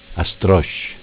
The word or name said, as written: A strosc